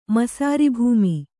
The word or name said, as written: ಮಸಾರಿಭೂಮಿ